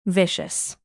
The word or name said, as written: vicious